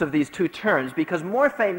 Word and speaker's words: morphe: The one was morphe, M-O-R-P-H-E, long E.